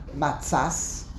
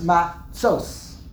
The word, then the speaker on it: matzos: The tzadi, the way you pronounce the tzadi, is not matzas, not matzus, not matzes, it's matzos. Tzos. The vav is there for one reason only, to tell you that the way you pronounce the tzadi is a cholam, matzos.